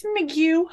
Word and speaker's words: McGeough: It's McGill. I have very low expectations for folks' ability to pronounce it.